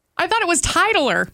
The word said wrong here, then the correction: titular: Oh. – Oh. – Is it titular? – Oh. – There's another one I've been pronouncing wrong.